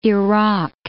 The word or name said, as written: Iraq